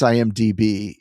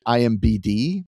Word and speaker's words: IMDB: I don't think he realizes he can't pronounce IMDB. He always pronounces it IMBD.